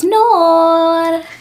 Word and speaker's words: Noor: It's pretty much as you spell it. So it's N-O-O-R.